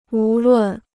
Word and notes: 无论: wú lùn

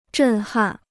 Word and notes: 震撼: zhèn hàn

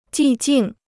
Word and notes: 寂静: jì jìng

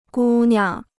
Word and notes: 姑娘: gū niang

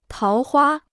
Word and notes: 桃花: táo huā

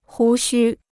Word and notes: 胡须: hú xū

